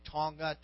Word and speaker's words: Tonga: I just came back from the South Pacific and island country of Tonga, T O N G A, but they don't pronounce the G.